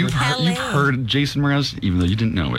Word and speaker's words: Mraz: And also, I didn't know if you pronounce his name Mraz or what. – Mraz.